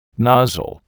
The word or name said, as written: nozzle